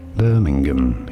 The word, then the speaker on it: Birmingham: Birmingham, you do want to stress on that first bur syllable. Birmingham.